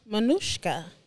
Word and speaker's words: Manouchca: Manouchca.